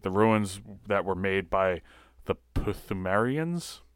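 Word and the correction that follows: Pthumerians: Themarians. It's Themarians. It's just like a silent P. – Good old silent P's. – Like pterodactyl.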